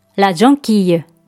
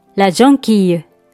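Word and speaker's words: jonquille: 気づい線は女性名詞で、ラジョンキーユ、ラジョンキーユと発音します。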